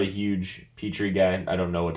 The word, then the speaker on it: Petrie: After that, Jalen Petrie. Peter? I don't know how to say his name. – You can say Petrie.